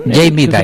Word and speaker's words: Jamie Dimon: Oye, a ver, este banquero, a ver, yo dije pésimo su nombre el día de ayer, es Jamie Dimon, ¿es Dimon? – Jamie Dimon.